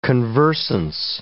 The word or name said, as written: conversance